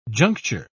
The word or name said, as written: juncture